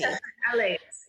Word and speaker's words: phthalates: Phthalates.